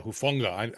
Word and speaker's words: Hufanga: Yeah, Hufanga.